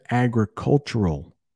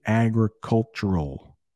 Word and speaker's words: agricultural: The early economy of Phoenix was mostly agricultural. Agricultural. That L sound at the end is a tough one.